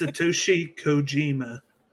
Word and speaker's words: Satoshi Kojima: It's Satoshi Kojima. – Kojima, there we go.